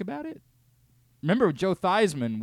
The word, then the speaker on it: Theismann: Theismann. Because it rhymes with Heisman and maybe improves your chances of winning the trophy.